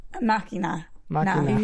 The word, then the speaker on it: Machina: No, he got it wrong. – Is it wrong? Ex Machina. – Machina. – He's forgetting the N.